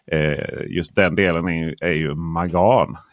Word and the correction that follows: mangan: Heter det? Mangan.